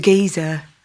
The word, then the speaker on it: Giza: I want to go to Giza. – Yes. Yes. Mm-hmm. Is that how you pronounce it? – Yes. Giza.